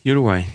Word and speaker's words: Uruguay: You grew up in Uruguay? – Uruguay, yeah.